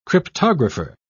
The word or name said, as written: cryptographer